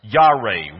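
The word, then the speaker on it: Yare (יָרֵא): The word for fear, I've got on the screen, and I just transliterated. I wrote it in English so we could pronounce it. Yare, Y-A-R-E. Sounds nowhere near what we pronounce as fear. But let me tell you,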